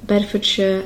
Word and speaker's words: Bedfordshire: So again, the ones we had here were Hertfordshire, Bedfordshire, and Cambridgeshire. And you can hear that I'm not pronouncing it as shire, but sh. It's spelled S-H-I-R-E, but I pronounce it more like S-H-U-H, like sh. So that's something that you can practice.